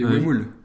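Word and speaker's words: Huemul: Un petit mot sur les Uelmus. – Les Uemul. – Je le prononce mal.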